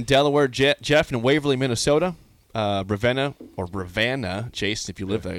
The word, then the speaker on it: Ravenna: But it's spelled R-A-V-E-N-N-A. There's no van in that. It's Venna. Ravenna.